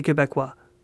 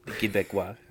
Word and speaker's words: Québécois: Just as Western Canada does not feel represented by the rest of Canada, you're just like the Québécois. And see, I said that correctly too. – Québécois.